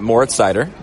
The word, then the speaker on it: Moritz Seider: Yeah, Moritz Seider. – Moritz Seider, defenseman from Germany.